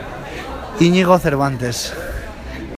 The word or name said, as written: Inigo Cervantes